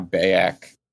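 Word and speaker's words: BAYC: That's Bored Ape Yacht Club. That's how we're pronouncing it now, Bayak.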